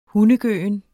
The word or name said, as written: hundegøen